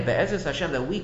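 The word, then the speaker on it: Be'ezras Hashem (בעזרת השם): We say Be'ezras Hashem, but it's really a reference to the Shem Havaya. The Hei is really a reference to the Shem Havaya. Be'ezras Hashem, that's how we pronounce it.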